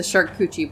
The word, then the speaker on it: charcuterie: Say it right. – Yes. – It's a charcuterie board.